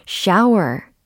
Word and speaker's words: shower: shower, shower, shower. 이렇게 해주시면 되겠습니다. 샤워가 아니라 shower, shower. 이렇게 앞에 발음도 샤 이렇게 해주셔야 되고 뒤에 발음은 워 이렇게 해주셔야 되겠습니다.